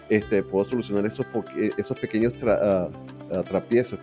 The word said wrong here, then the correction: tropiezos: ¿cómo se dice? Se dice tropiezos.